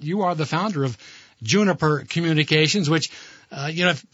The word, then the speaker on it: Junapr: It's spelled differently, like you said. It's J U N A P R, and it's a play on my maiden name, which is Junas, yeah.